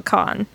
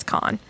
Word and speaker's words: Kahan: We're talking about Noah Kahan. I think that's how you say his name. I think in interviews, that's how he said it. It's spelled like Kahan, but I think it's Khan.